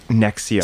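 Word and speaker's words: NXIVM: The cult is called Nexium. – Nexium. – So N X I, then it's like a V.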